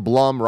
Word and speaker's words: Blum: And then, okay, so Gresik writes in and says, for Blumhouse, the Blum rhymes with plum, and I need to remember that.